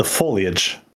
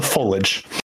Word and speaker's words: foliage: I've heard it pronounced both ways. It's the older people usually say foliage. – Yeah. Yeah. There's an I in there.